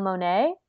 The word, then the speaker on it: Monáe: And you know who else's performance was dope as frick was Janelle Monáe.